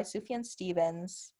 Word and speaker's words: Sufjan Stevens: It's Chicago by Sufjan Stevens.